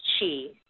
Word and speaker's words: Qi: So, Peter, why don't we start out by just explaining what exactly is Qi? – Okay, I'm sure everyone has heard that term, and it's actually spelled several different ways, but it's pronounced just like you pronounced it.